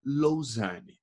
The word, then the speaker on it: Lausanne: Falei certo a pronúncia? Né, Me conta a sua viagem, e aí? – Fala, Filipão, beleza? Beleza, e você, cara? – Obrigado pelo convite, primeiramente. E se falou certinho, sim, é Lausanne.